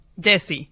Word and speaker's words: Desi: Desi. Do I have that pronunciation right? – Desi.